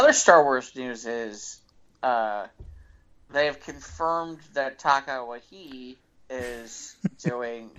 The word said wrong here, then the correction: Taika Waititi: did I get his name wrong? – Taika Waititi. – Taika Waititi.